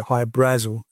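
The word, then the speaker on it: Hy-Brasil: Well, that's not how you pronounce it. You pronounce it High Brazil. That's how the Irish pronounce it, which is a mythical submerged island off the southwest coast of Ireland.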